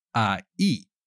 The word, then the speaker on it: ahí: Now, there is also ahí. The H is never pronounced.